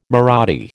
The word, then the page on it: Marathi: American English